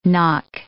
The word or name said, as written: knock